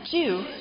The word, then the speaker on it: dew: You say it that way. Jew. Right. Don't have a hard time with that one. Right. Well, we say it like do, like do Lord, oh do Lord, oh do remember me. But it's just spelled different. It's like Jew. No, she said do it with a J. Jew. Jew. Right. I'll just spell it. D-E-W.